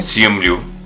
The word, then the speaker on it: Simu Liu: Así que, Pues hablando de Shang-Chi, el actor Simu Liu, si se dice así, ¿no? – Sí. – Simu Liu.